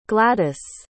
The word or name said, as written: gladyce